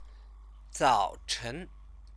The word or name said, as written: zaochen